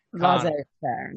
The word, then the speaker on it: laissez-faire: Laissez-faire, I think, is the pronunciation.